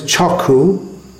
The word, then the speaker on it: Chaku: The word is Chaku. It's Bengali pronunciation of Chakshu. Chakshu means eye. Eye. The word, this sound which is written KS with a dot underneath it, A, as appears in the word Kshatriya, for instance. That is pronounced, that Ksh sound in Bengali is pronounced K. So Chakshu becomes Chaku, and somehow devotees of let's get back to the meaning of this.